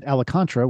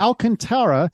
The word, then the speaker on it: Alcantara: I always pronounced it Alicantara, which is not even close to correct, but nope. It's Alcantara or close to that anyway.